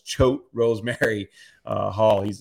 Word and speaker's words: Choate Rosemary Hall: I got corrected last time. I remember I couldn't pronounce the school. It's Choate Rosemary Hall.